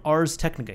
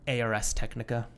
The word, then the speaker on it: Ars Technica: Found out I was saying it incorrectly for a while. But what were you saying? – ARS Technica. – Oh, yeah. – It's Ars Technica. – It is, yeah.